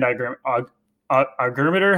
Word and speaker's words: ergometer: A pro would be doing the cycle hand ergometer.